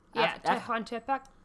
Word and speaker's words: Tehuantepec: the Tehuantepec isthmus. – Tehuantepec.